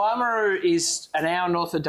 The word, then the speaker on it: Oamaru: So 18 year old Dunedin double cask, and then you guys are based in Oamaru.